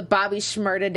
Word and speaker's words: Bobby Shmurda: Gosh, we were trying to do the Bobby Shmurda dance.